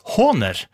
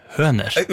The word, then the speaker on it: Hohner: Hohner er en tysk produsent av trekspill, munnspill og andre instrumenter, uttales Hohner, og ikke med omlyd høner.